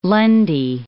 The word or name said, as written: Lundy Island